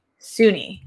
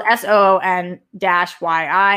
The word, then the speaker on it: Soon-Yi: Soon-Yi, I think is how it's pronounced. Spelled S-O-O-N-Y-I, and I think it's Soon-Yi is how it was pronounced.